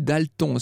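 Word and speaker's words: d'Alton: Je dis Dalton, c'est une vieille famille irlandaise, mais on avait coutume à l'époque de prononcer à la française. Pour une fois, Dalton d'apostrophe Alton.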